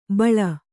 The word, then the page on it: ಬಳಿ: baḷi